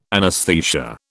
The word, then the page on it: anesthesia: British English